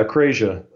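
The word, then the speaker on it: akrasia: But I mean, what is the acrasia effect? How do I say that? – Yeah, acrasia.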